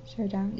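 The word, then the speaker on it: Shodown: Showdown? Yeah, I said that right.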